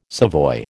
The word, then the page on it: savoy: American English